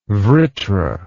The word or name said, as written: vritra